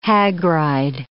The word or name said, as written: hagride